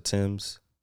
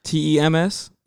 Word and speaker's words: Tems: I guess I guess you could pronounce it Tim's Tems. T E M S. Yeah, Tems.